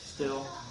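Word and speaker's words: Stihl: I don't know how you pronounce it. – Steel. – Steel. – Steel. Steel. Be still. – Be still? Okay, still. It's a German company.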